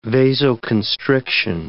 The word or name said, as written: vasoconstriction